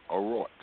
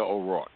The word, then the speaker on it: O'Rourke: Is it how do you say it? – O'Rourke. Beta O'Rourke. – O'Rourke. Okay. O'Rourke. Okay.